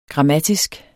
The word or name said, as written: grammatisk